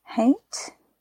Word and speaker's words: haint: In the southern US, painting the porch ceiling, now it's Huint, H-A-I-N-T, haint, haint.